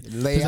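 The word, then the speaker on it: Leão: I was talking to one of my Brazilian training partners, and he was like correcting me on how to say it. – Leao. – Like, Leao. He just said it the way that he said it Leao. with the accent. I couldn't match, but my favorite glasses, I actually wear these.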